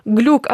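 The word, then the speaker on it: Glück: Одні кричали «Глік», тому що в неї єврейське походження, ага. Це читається як «Глік». Інші кричали «Глюк», тому що в неї також угорське походження, і це читається як «Глюк».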